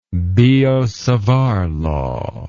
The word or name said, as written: biot savart law